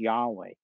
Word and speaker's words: Yahweh: Scholars conjecture or kind of speculate that the vowels are A and E, so it's probably was originally pronounced Yahweh.